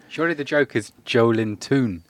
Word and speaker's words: Joelinton: Joelinton, how should you pronounce his name? – Joelinton. – Joelinton. – Yeah, the emphasis is on the L. – Joelinton. – Joelinton. So people are making that joke saying, oh, he's Joe Linton. But more accurate would be... – Joelinton.